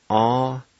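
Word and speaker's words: atlvdi: Number four, tire, a-sle-di.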